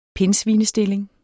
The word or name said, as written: pindsvinestilling